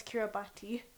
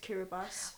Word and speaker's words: Kiribati: I used to say the country of Kiribati as Kiribati, even though it's pronounced Kiribati.